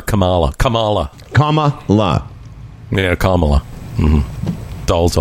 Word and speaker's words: Kamala: It wasn't Kamala. Until, it wasn't until those little kids came out and showed us all how to pronounce her name. Kamala.